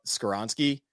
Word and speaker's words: Skoronski: And for everything we kind of said about Skoronski, is, I might have said that wrong.